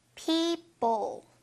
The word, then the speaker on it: people: 이렇게 ㅅ 발음 세게 하지 말고요. People. 요렇게 들릴 겁니다. 약간 쌍비읍처럼.